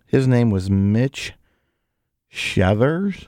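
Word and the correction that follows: Schauer: It's actually pronounced Shower. – Shower? – Yeah. – Oh, it is? Yep. Okay. That's weird. – I know. I looked it up. – It's not spelled like shower.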